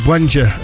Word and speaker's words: Buenger: Bunga, B-U-E-N-G-E-R. – I pronounce it Wenger. – We'll go with that. Tom Wenger then.